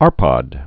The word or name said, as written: Arpad